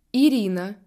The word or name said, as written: Irina